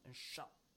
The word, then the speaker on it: أشق: أشق The stress is on أشق See, لَهُمْ عَذَابٌ عَذَابٌ Ba ends with a tanwin, right? – Bun.